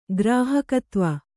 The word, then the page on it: ಗ್ರಾಹಕತ್ವ: Kannada